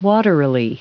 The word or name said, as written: waterily